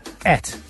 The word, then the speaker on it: ate: No, A-T-E. Et. – You pronounce, that's what I mean, you pronounce that in the Gloucester accent. Et. – Because I'm from Gloucester. – You are. – If I was from London, I'd say 8.